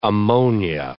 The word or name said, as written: ammonia